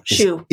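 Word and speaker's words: Choux: The next one comes to us from Left Chow, C-H-O-U-X. I think that's how you pronounce it, right? – Shoe. – It's shoe. – Oh. – Like shoe pastry.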